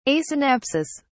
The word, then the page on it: asynapsis: British English